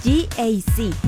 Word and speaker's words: GAC: No se dice GAC, se dice GAC. Nuevamente, GAC, y se maneja mejor de lo que se pronuncia.